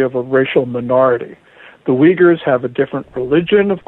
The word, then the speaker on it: Uyghurs: Is that how it's pronounced? – Right. Uyghurs are Turkic Muslims.